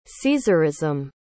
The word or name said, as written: caesarism